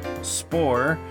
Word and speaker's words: Spore: His name is Chuck Spore, which he says rhymes with duck score, so I hope that got that right.